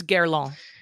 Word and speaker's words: Guerlain: The next one on the list, which I think is interesting, and I hope I say it right, it's Guerlain, right? How yeah. do you say it? – Right? Guerlain. If you're going to be French, it's Guerlain.